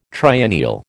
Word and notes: triennial: American English

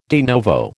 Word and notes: de novo: American English

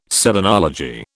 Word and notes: selenology: British English

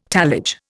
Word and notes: tallage: Indian

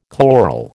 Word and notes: chloral: American English

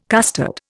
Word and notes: costard: Indian

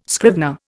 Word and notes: scrivener: Indian

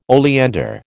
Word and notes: oleander: American English

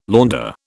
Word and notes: Länder: British English

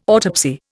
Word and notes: autopsy: Indian

